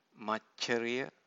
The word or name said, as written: maccariya